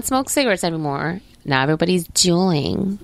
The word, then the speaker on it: Juuling: It's J-U-U-L-I-N-G. Juuling. Maybe I'm saying it wrong. – I read it as Juuling. – I think when you have two U's next to each other, it's not a W. I think it's an U.